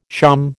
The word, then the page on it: shawm: American English